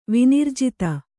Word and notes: ವಿನಿರ್ಜಿತ: Kannada

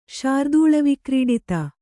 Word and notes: ಶಾರ್ದೂಳವಿಕ್ರೀಡಿತ: kannada

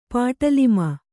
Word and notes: ಪಟಾಲಮು: paṭālamu

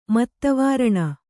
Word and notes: ಮದವಾರಣ: madavāraṇa

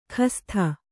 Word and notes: ಖಸ್ಥ: Kannada

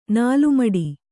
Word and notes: ನಾಲ್ಮಡಿ: Kannada

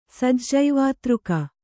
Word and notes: ಸಜ್ಜೈವಾತೃಕ: Kannada

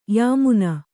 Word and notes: ಯಮನ: yamana

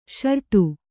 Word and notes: ಷರೇಡು: ṣarēḍu

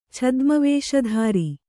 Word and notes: ಛದ್ಮವೇಷಧಾರಿ: Kannada

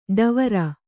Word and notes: ಢಾವರ: ḍhāvara

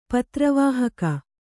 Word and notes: ಪತ್ರವಾಹಕ: Kannada

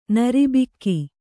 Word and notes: ನೀರುಬೊಕ್ಕೆ: nīru bokke

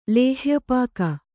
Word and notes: ಲೇಹ್ಯಪಾಕ: kannada

